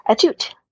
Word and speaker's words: Atout: How do you pronounce it? – Atout. – Okay. There you go. See? Easy enough. Alicia Atout. – I know. It's so simple. Everyone's kind of scared to say Atout because they don't want to be offensive, but it's literally what it is.